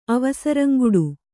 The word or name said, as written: ಅವಸರಂಗುಡು